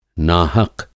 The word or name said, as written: नाहक़